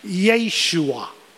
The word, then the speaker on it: Yeshu: The Hebrew word for Jesus, I believe most of you would know, is Yeshua. Or Yeshua, some pronounce it Yeshua. The rabbis, though, throughout the years have changed the name and dropped the last A, and they call him Yeshu.